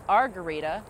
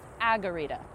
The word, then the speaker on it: Agarita: I thought it was Argarita, like margarita without the M. It is Agarita. It is Agarita, not Argarita.